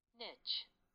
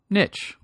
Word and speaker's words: niche: Since they already pulled up Merriam-Webster, let's let's hear what Merriam-Webster has to say. Niche. Do you hear that? Let's do that one more time. Niche. Niche but it also has alternative pronunciations niche and niche. I would have never guessed niche.